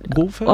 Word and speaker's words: Wolfhard: Finn, is it Wolfhard? Is that Wolford? – How you? – Wolfhard. – Oh, Wolf. – Yeah. – Wolfhard. – Wolfhard. – There we go. Wolfhard. Well done on the pronunciation.